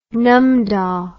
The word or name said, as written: numdah